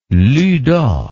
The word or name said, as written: luta